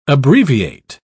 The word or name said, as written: abbreviate